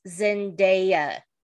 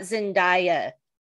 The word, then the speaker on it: Zendaya: It's Zendaya, not Zendaya. Zendaya even has a video where she tells people how to pronounce her name because they screw it up all the time.